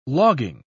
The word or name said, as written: logging